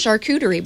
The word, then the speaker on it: charcuterie: Charcuterie boards.